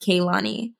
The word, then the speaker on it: Kehlani: There's lots of different ways to pronounce it, but my mom spelt it this way, so it's just Kehlani.